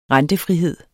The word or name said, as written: rentefrihed